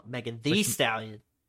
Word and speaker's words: Megan Thee Stallion: Megan Thee Stallion. Say it right. – Oh, you gotta enunciate. The. – Yeah. It's two E's, isn't it? – Yeah, it is.